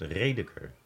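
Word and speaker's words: Rädecker: Radeker. A met een omlaag. Hoe spreek je dat uit? Redeker. Redeker maar niet te Duits liever.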